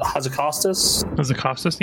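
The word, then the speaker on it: Hazzikostas: how do you say it? Hazakostas? – Yeah, that's close enough.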